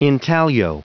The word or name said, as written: intaglio